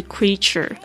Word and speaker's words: creature: 我们这边呢特别来记忆几个单词，第一个是 creature, creature, C-R-E-A-T-U-R-E, 特别注意一下 E-A 这个这边的发音，发长音的这个 E, creature, creature, 动物、生物、人，或者是指创造物。 Rib, R-I-B, rib, 就是肋骨，或者是如果你喜欢吃这个排骨的话呢，就是 rib, 在那个有时候可以在一些美式餐厅可以吃到这种烤肋骨，非常好吃，我自己非常喜欢。